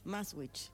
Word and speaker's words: Maastricht: ¿cómo se dice? Ahí, decilo vos, que vos te sale tan lindo. – Maastricht. – Ahí está. Maastricht.